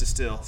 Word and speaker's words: Destihl: I have liked the last couple of things by Distill I've had, and that's spelled D-E-S-T-I-H-L. – Uh-huh. – I think 3Sex tells me it's pronounced Distill, so we'll assume that she's right.